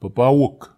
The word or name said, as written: papawuk